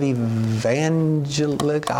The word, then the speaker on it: evangelical: 73% of evangelical, I haven't said it. Say it. – Evangelical. – Thank you. I got to remember that long E, don't I?